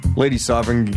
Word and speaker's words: Lady Sovereign: Lady Sovereign, yeah, there you go.